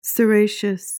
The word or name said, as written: ceraceous